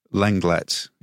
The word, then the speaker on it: Lenglet: Yes, whereas I suppose the fact that Lenglet is in here as well from Tottenham.